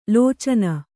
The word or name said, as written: ಲೋಚನ